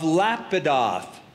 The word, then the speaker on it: Lapidoth: The description is, now Deborah, a prophetess, the wife of Lapidoth. Now, I know that's a weird name for a dude, right? Some of you are thinking that, Lapidoth. The Hebrew pronunciation is Lapidot.